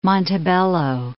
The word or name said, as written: Montebello